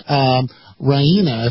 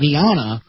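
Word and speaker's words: Rania: So obviously the Queen of Jordan is named Queen Raina, if I'm pronouncing that correctly. But he pronounced it as Queen Rihanna, who I believe is a pop music star.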